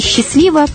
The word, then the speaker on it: счастливо: Счастливо или счастливо провести масляную неделю. Это хорошо. Но обратите внимание на ударение. В прощальном приветствии только счастливо. Счастливо оставаться. Всего доброго, добрых встреч и добрых слов.